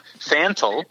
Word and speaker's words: Fantel: Fantel, but that's close. Like Mickey Mantle.